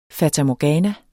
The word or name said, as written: FATAMORGANA